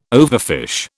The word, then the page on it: overfish: British English